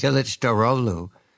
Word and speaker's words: Kılıçdaroğlu: I see that his opponent, Kemal Kılıçdaroğlu, is that how I say that correctly? – Yes. – Kılıçdaroğlu is making the cost of living a major piece of his campaign.